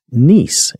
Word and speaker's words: Nissi: So I went with, you notice the phonetics, Nice, okay, N-E-E-C-E. So I went with the Nissi spelling, even though it's pronounced Nice.